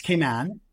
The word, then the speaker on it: Cayman: He's the area director for Kimpton Seafire Resort and Spa down in Grand Cayman, as I've been told, is the correct pronunciation. Is that right? – Alyssa, you were close. You were close. It's Cayman.